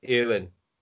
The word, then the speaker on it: Eowyn: Eowyn.